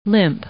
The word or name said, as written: limp